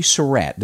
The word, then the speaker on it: Surratt: Her name is Mary Surratt.